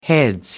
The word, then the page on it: heads: British English